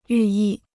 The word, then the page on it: 寓意: yù yì